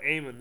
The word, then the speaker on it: Eamon: It's a hard name to pronounce when you see it on paper, but Eamon, yeah.